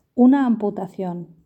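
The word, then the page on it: una amputacion: Spanish (Spain)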